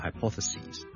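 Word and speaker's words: hypotheses: Plural 我哋系 S I S 转咗做 S E S，就变咗 hypothesis，记住个发音，OK？由 sis 转咗做 sees，你当好似系 C S E A 或 S E E 嗰个 C，跟住再加多个 S， so singular hypothesis， plural hypothesis， singular crisis， plural crises。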